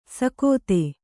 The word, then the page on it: ಸಕೋತೆ: kannada; sakōte